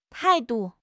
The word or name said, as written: tai4 du5